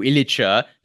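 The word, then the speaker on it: Ilić: But so people like our listeners will regularly comment on like cringing when I refer to Rapids goalkeeper Marko Ilicha because I spoke to him and, you know, I said, how do you want me to pronounce your name? How do you pronounce it? And he said, that's how you pronounce it.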